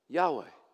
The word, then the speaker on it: Yahweh: now how do we pronounce that by the way yahweh you know how they got to that by the way they took the the vows of Adonai for Lord.